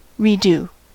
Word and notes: redo: American English

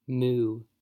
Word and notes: moo: American English

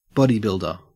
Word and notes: bodybuilder: Australian English

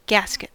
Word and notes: gasket: American English

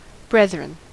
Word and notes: brethren: American English

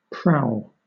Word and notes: prowl: British English

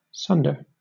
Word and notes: sunder: British English

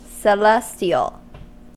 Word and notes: celestial: American English

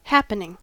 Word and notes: happening: American English

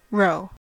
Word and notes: rho: American English

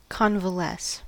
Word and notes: convalesce: American English

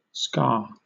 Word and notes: ska: British English

